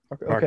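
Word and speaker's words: Varcoe: But obviously I know Gary Ablett Sr. I love watching his highlights, school kicking, and Travis Varco, I think I'm pronouncing that right. – Mm-hmm. Yep. – Varco. Okay. Yeah.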